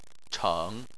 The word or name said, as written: cheng3